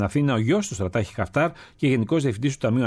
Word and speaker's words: Χαφτάρ: Ο κύριος Χαφτάρ, ο Μπελκασέμα, το προφέρουμε σωστά, Χαφτάρ, είχε συνομιλίες με τον Πρωθυπουργό, τον Υπουργό Εξωτερικών και τον Υφυπουργό Εξωτερικών, Κατερίνα.